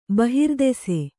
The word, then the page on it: ಬಹಿರ್ದೆಸೆ: bahirdese